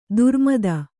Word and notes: ದುರ್ಮತ: durmata

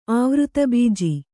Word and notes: ಆವೃತಬೀಜಿ: āvřta bīji

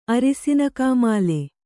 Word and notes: ಅರಿಸಿನಕಾಮಾಲೆ: arisinakāmāle

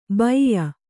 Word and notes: ಬೈಯ: baiya

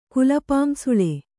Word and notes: ಕುಲಪಾಂಸುಲ: kulapāmsula

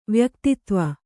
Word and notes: ವ್ಯಕ್ತಿತ್ವ: vyaktitva

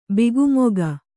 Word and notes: ಬಿಗುಮೊಗ: bigu moga

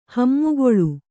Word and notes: ಹಿಮಗಾಳಿ: kannada

